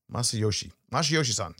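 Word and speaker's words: Masayoshi Son: How do you say his name? Masayoshi-san. Yeah, that's it.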